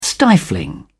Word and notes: stifling: British English

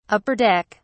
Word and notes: upper deck: British English

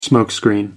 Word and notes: smoke screen: British English

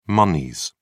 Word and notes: moneys: British English

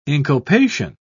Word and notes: inculpation: British English

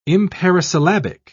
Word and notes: imparisyllabic: British English

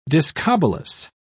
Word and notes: discobolus: British English

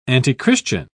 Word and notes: antichristian: British English

